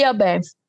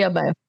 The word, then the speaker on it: ABEMF: We all say ABEMF. Okay. We all say ABEMF. – A BEMF. Okay, yeah. Perfect.